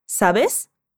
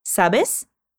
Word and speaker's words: sabes: Repete, sabes? Aqui é importante pronunciar o S no final, porque isso é o que indica que estamos tratando a outra pessoa de tu, ou seja, é um tratamento informal, mais íntimo.